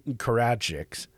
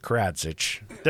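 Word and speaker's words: Karadžić: So the leader of Serbia, Radovan Karadzic. – Perfect. That's exactly how it's pronounced. – Karadzic. There we go.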